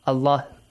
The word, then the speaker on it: Allah: How the word Allah is pronounced? Bismillah. The next in Surah Al-Ikhlas. Qul Huwallah. The lam is like heavy lam. Okay? It is conditional. This is temporary. Okay, temporary heavy sound.